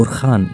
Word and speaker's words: أورهان: آه، توفي عثمان وكان ولده أرهان قد تمكن أو أرخان يعني بالعربي طبعا بالعربي تلفظ بالخاء